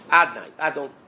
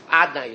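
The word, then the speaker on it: Adonai: How do we pronounce it? Aleph, Dalet, Nun, Yud. Adnai. You don't say, right? Adnai is this called. It's written Yud-Kei Vav-Kei, and it's written Adnai. I mean, it's pronounced Adnai.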